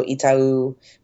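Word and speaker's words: Itaú: Is, is also that how you pronounce that word? – Mm-hmm. I never would have gotten that right. – Yeah. Oh, Itau. Itau. Okay. Yeah, Itau. I would have said like Itau.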